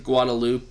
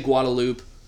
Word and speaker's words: Guadalupe: Uh, the Guadalupe, well, depends on if you grew up in the area. People say Guadalupe. Uh, obviously, it's pronounced Guadalupe you know. But if you grew up in the area, like in Austin, we say Guadalupe.